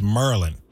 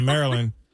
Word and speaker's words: Maryland: How we say it in Maryland is Merlin. – Merlin. – Merlin. – Yeah, Maryland.